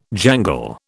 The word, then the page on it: jangle: British English